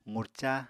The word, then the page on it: qurca: apatani